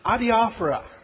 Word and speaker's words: adiaphora: And that concept is, another word, pronounced adiaphora.